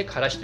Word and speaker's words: 芥: アクタという字でからしと読みますでこのからしな菜の花みたいこの菜っぱから分かりますけれどもねこういうものです。